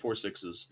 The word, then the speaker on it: Four Sixes: Yeah, that's how you say it. Four Sixes. It's a ranch in Texas, and one of the characters left Yellowstone.